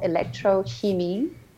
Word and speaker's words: Elektrochemie: It's I was always Thomas and I, our first band was called Elektrochemie, which is a German word.